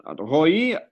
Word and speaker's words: Roei: Hello, Roei.